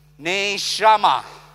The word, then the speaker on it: neshama: And that word is neshama. Everybody say together, or let me say, let me teach you how to pronounce first. It's pronounced as N-A-Y, ne, S-H-A, sha, M-A-H, ma. Ne, sha, ma.